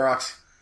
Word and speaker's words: Ferox: Maybe, I need to just suck it up and sit down and watch Cannibal Holocaust and Cannibal Ferox, or Pharaoh, or however you pronounce it. – Yeah, Ferox.